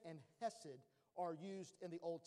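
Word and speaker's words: hesed (חסד): But it's hen, and it looks like C-H-E-N, but it's pronounced hen. And then hesed, and it's also wrote C-H-E-S-E-D.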